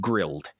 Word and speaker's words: Grill'd: How I was commissioning systems at a new restaurant, not a new a new location for this franchise called Grilled, and it's spelled GRILL'D.